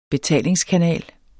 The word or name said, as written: betalingskanal